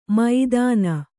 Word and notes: ಮೈದಾನ: maidāna; Kannada

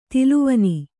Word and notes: ತೆಲ್ಲವಣ್ಣ: tellavaṇṇa

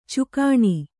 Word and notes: ಚುಕಾಣಿ: cukāṇi

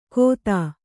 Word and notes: ಖೋತ: Kannada

